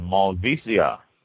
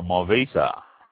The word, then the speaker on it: Malvasia: The next one, Malvasia, or Malvasia, pronounced both ways, spelled M-A-L-V-A-S-I-A, Malvasia.